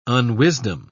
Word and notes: unwisdom: British English

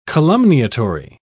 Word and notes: calumniatory: British English

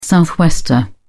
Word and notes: southwester: British English

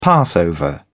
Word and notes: passover: British English

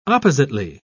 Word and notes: oppositely: British English

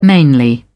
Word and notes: mainly: British English

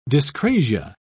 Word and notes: dyscrasia: British English

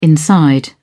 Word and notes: inside: British English

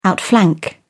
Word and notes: outflank: British English

